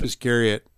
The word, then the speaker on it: Iscariot: Oh, probably Sleep Iscariot. – Sleep Iscariot. Did I say it right? – Yeah. Just like Judas.